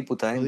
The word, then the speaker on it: Putaendo: Háblame entonces cuando tú saliste de Putaendo. Sí, Putaendo. ¿Lo dije bien? Sí, búsquelo en el mapa, es súper interesante.